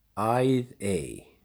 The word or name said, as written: Æðey